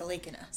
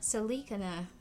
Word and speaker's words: Sallekhana: Research has focused on religious suicide, including the Jain practice of Sallekhana. Is that how you say it? – Sallekhana. – Sallekhana. Wow.